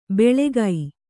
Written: ಬೆಳೆಗೈ

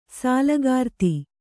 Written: ಸೋಲಕಾರ್ತಿ

